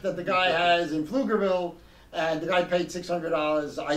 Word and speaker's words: Pflugerville: Apparently, he paid $600 for an airline ticket for Geno Smith to go to Pflugerville, Texas, which is spelled with a P.